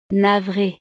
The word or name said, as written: navre